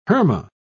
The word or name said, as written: herma